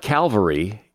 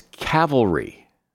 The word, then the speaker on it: cavalry: It's not Calvary. It's Cavalry. And here's one I hear a lot.